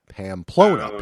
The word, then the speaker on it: Pamplona: How is it Plampona? Is it Plam? How do you say that? Pamplona. Pamplona. – Pamplona.